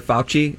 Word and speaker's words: Fauci: Side note: did you know that Dr. Fauci is how you pronounce his name? – Dr. Anthony Fauci.